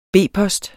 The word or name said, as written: B-post